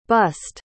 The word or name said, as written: bussed